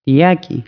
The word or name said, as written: vigilar